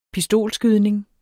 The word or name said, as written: pistolskydning